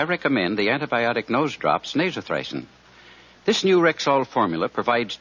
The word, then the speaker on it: Nasothricin: So if a cold in the nose threatens to hinder your holiday enjoyment, I suggest you get a bottle of Rexall Nasothricin. That's spelled N-A-S-O-T-H-R-I-C-I-N.